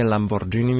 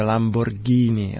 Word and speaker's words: Lamborghini: rekli nije Lamborghini, nego je Lamborghini.